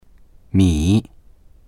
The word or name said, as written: mi3